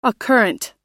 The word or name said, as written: occurrent